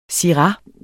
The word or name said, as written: SYRAH